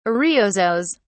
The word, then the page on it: ariosos: British English